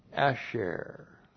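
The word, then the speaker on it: Asher (אשר): The relative pronoun Asher. Looks like that in the Hebrew, Aleph, A-S-H-E-R, Asher.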